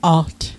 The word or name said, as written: alt